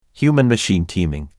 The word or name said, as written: Human-machine teaming